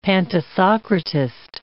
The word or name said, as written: pantisocratist